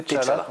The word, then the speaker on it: T'Challa: fringant en Chala, King Chala. King... Alors moi je vais dire alors que c'était T'Challa, mm.